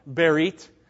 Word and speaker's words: berit: And by the way, I mispronounced it. Isn't that great? You know, a couple of you noticed that I threw a sh in the middle of berit.